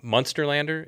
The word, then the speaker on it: Munsterlander: Munsterlander, is that how you mm-hmm.